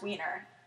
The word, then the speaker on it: Weiner: And yes, it's pronounced Weiner. We looked it up. You might think it was Weiner.